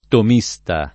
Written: tomista

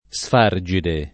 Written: sfargide

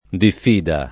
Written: diffida

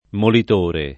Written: molitore

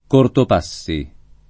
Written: Cortopassi

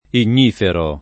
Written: ignifero

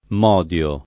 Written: modio

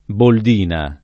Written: boldina

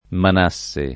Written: Manasse

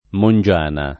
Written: Mongiana

